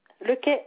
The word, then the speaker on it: Lequet: Lequet, L-E-Q-U-E-T.